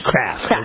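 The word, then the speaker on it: Krafft: Or is it Kraft? – Kraft. – Okay. – Yes. – A little extra vowel in there.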